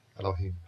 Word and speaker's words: Elohim: Bereshit bara Elohim. – Bereshit. – Bereshit. – Bara. – Bara. – Elohim.